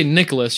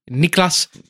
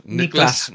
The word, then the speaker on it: Niklas: And also, when you say, you can't say Nicholas, you got to say Niklas Antilla. – Niklas. – You got to say it like it's pronounced.